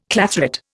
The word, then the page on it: clathrate: Indian